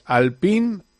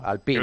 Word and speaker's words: Alpine: Pues eso, hay que decir Alpine en francés.